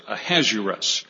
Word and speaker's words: Ahasuerus: I actually looked up the pronunciation of this, Ahasuerus.